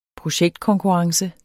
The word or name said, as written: projektkonkurrence